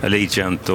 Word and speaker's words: Allegiant: Allegiant och Idaudi Tiar. – Allegiant, vad bra uttal.